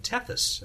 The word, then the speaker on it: Tethys: I saw also that we're not too far away from what will, I guess, be only the second of two flybys of Tethys. Am I pronouncing that correctly? – Right.